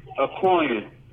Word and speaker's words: Akognon: Akognon.